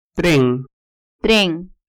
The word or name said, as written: tren